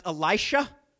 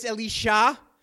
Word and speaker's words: Elisha: And I believe in English it's normally pronounced Elisha, the guy that came after Elijah. But in Hebrew, it's Elisha.